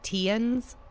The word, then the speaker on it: Theuns: His name is spelled T-H-E-U-N-S, but they pronounced it Teuns.